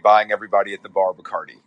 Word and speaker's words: Bacardi: But Bacardi is the most popular like store brand, correct? – Yeah, it is. It's the Budweiser of rum, and also it rhymes with party, so it really has been the subject of a lot of lazy lyricism over the years.